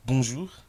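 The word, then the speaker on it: bonjou: On dit bonjour, on ne prononce pas le R à la fin, mais on dit bonjour, ou on dit peut-être sarafette.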